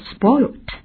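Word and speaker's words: spoilt: 注意这个词过去式、过去分词有两种变化，一种变成加 ed 的读作 spoiled, 那么另外一种呢特殊变化变成了 spoiled。 Spoiled, 直接加个 T,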